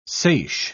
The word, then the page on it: seiche: British English